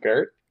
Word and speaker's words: Goeth: Meanwhile, SS Lieutenant, here we go with the names, Eamon Goeth. – I am tempted to say Eamon Goeth, like, because it's Goethe. Almost Goethe, right? – Right. – G-O-E-T-H minus the last E, so maybe just Eamon Goeth.